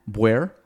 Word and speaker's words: Boire: We'll go into his attire in a little bit, but Matt, I'm going to pronounce it the way you, Matt Boire? – Yeah, you got it exactly right. I nailed it, Matt Boire, because I had a cheat sheet here.